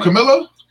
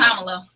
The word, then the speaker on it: Kamala: Oh, Camilla? – I can't say her name, so I shouldn't. – Kamala.